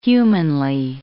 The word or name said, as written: humanly